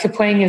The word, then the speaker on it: Kapring: Oh, well, Kapring and Kapring are both fine. But Kapring is sort of like an onomatopoeia, you know, like Kapring, something bouncing off of something else.